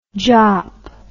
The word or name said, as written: jaap